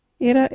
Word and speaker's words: irra: Our local wiggity word is itter. What is itter? Itter is your mouth. You can use itter in this sentence. Wipe that child's itter. Itter is spelt I-R-R-A.